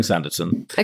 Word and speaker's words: Sanditon: Sanditon. – Yeah. What are we calling it for this? Sanditon. – Okay, great. – Let's stop saying Sanditon.